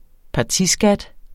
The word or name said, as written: partiskat